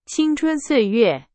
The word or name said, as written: 青春